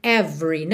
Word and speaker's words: every: Aqui, atenção que o é do meio de every, não é pronunciado, ok? Então, você já vai do V pro R, every, every.